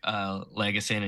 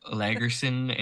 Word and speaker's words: Lagesson: I didn't realize how badly I was butchering the Swedish names when he said like Lagesson and Yarncroke's name. And it was like Lagerson and Yanekrook or something.